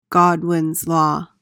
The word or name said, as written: godwins law